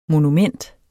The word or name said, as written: monument